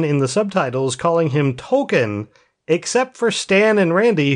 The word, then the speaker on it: Tolkien: At one point, they reveal because he's the token black kid, but they reveal that his name's not Token. His name is Tolkien because his parents love J.R.R. Tolkien. So everybody has been pronouncing it wrong, and then he finds out that his friends are all calling him Token, and he's like, "Wait, you're calling me what?"